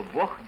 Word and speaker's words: бог: Вместо «г» в конце слова является «к», кроме слова «бог», где произносится «х».